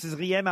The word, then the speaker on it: Zriem: Comment je dois prononcer Z-R-I-E-M ? – Ah ben Zrim, tout Zrim. Simplement. Voilà. – Tout simplement, Brigitte Zrim à Auxerre.